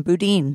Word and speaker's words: Boudin: And the legendary San Francisco bakery called Boudin. What's the right way to say it? – You got it the first time, Boudin.